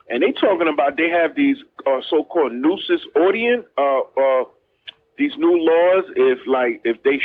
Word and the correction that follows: ordinance: I'm pronouncing it wrong. But where if you have crime happening around your business, they can shut you down. – Oh, yeah. – Your business yeah, ordinance. Nuisance.